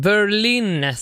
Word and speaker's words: Berlin: Take My Breath Away med Berlin, som det ska uttalas på engelska och inte på något annat sätt.